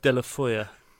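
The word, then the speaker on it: Deulofeu: How do you pronounce it, mate? – De La Foya. – De La Foya. – It sounds like a cake. – It's certainly not Delphaloo.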